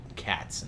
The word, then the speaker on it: Khatz: But reading the story, it's about a land where in the past there used to be these creatures called cats, and it's spelled K-H-A-T-Z, but they are actually like large felines, panthers, tigers, lions, and they were bonded.